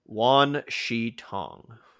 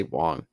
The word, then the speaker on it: Wan Shi Tong: Wan Shi Tong. – Wan. There you go. I always want to say Wong. – No, yeah, it's W-A-N, excuse me, not W-O-N-G.